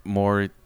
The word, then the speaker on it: Morey: And Daryl Morey... Am I saying it right? – Yeah.